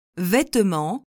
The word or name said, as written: vetement